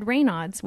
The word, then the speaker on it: Raynaud's: Um, I did think I had Raynaud's, which is not pronounced Raynoids, like I said in past episode.